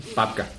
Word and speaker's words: babka: Babka. – I got the bubka sitting in front of me. – Babka. – Did I say it wrong? – Mm-hmm. – I got the bubka, is that it? – Perfect.